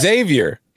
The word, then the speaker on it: Xavier: I think no, it's Xavier. – It's Xavier. – Okay, yeah. Now there are some recruits who pronounce the X.